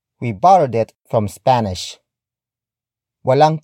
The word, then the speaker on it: walang: Walang kurtina ang bintana.